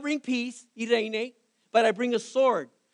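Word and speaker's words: irene (εἰρήνη): The word peace is a Greek word pronounced irene.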